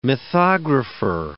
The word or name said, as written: mythographer